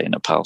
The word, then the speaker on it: Nepal: And also the fact that I knew I wanted to spend some more time in Nepal, or Nepal, depending on how you say it. – Yes. – Yeah, the locals say Nepal, so we'll go with Nepal for now.